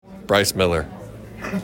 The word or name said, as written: Bryce Miller